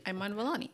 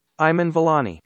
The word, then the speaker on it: Iman Vellani: Iman Vellani. – Yes. So I have the pronunciation. Let me. – Iman Vellani. – Oh, Iman Vellani.